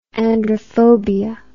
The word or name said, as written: androphobia